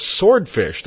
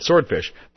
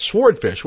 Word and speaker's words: swordfish: I believe it's pronounced swordfish. The W is silent. I think it's a common mistake since I asked my daughter Lauren, the college kid. She pronounced it swordfish, but Carly, my high school kid, pronounced it swordfish with the W.